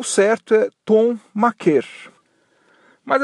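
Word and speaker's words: Thon Maker: E ele esclareceu que a pronúncia correta do sobrenome dele, segundo o próprio jogador, é Tom Maker. Tom Maker. E não Maker né, até meio óbvio né, que não seja maker.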